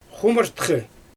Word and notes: xumurtxɯ: Xyzyl

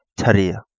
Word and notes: Tariha: Kapingamarangi

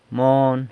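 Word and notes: mon: San Lucas Quiaviní Zapotec

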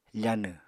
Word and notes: fanw: Apatani

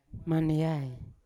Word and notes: maneai: Yawa

